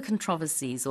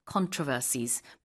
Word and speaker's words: controversies: and this is for all the controversies or controversies, both pronunciations are correct, that surround these horses.